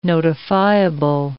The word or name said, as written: notifiable